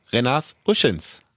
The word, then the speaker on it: Renars Uscins: Es ist äh, der Premierenbesuch beim Recken Podcast für Renas Uschins. Herzlich willkommen. Hallo, guten Tag. Renners, ich muss erst mal fragen, habe ich deinen Namen richtig ausgesprochen? – Ja, tatsächlich äh, hast du es gemacht,